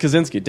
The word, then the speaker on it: Kaczynski: This is all stuff you cut out because I can't pronounce his last name. – Kaczynski. – Thank you.